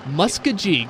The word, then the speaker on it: Muskogee: So it actually rhymes. Okie from Muskogee. – Muskogee. – Okie from Muskogee.